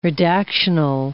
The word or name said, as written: redactional